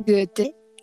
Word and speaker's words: Goethe: Nunca pronuncio bien a Goethe, ¿no? Goethe. – Goethe. – eso. Eso sonó muy bien, Goethe.